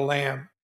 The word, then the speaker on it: lam: We arrested everybody at early, you know, zero dark 100 hours, except for one guy who went on the lam. And I like to say that it's spelled L-A-M. A lot of people put L-A-M-B, which is hilarious.